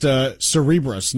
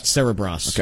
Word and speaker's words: Cerebras: I found out it's actually pronounced Cerebras, not Cerebras.